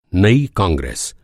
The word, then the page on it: कांग्रेस: کانگریس